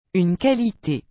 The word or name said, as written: une qualité